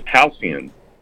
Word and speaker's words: halcyon: Okay, the word of today is halcyon. It's spelled H-A-L-C-Y-O-N, halcyon.